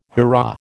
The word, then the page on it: hurrah: American English